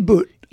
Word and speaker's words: Bout: And in return, we gave up a man named Victor Bout. I'm going to say Bout because that's how Google says it. You could say Boot.